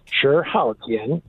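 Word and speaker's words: Chi Haotian: There was a guy named, in Chinese it's pronounced Chih Haotian, C-H-I, and then the next word is H-A-O-T-I-A-N.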